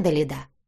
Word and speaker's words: Далида: Как вас зовут?» «Далида», — делая ударение на последнюю букву, ответила она. «А вас?» «Максимилиан. Ну, можно просто Макс. У вас редкое и красивое имя, Далида».